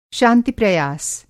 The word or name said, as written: शांति-प्रयास